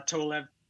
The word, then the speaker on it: Tolev: then there was yeah, how do you spell that? Tol- uh, Tolev, T O L E V, and Steph like Stephanie but short.